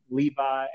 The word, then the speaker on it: Levi: Alan, A-L-L-E-N, Levi, L-E-V-I, Simmons.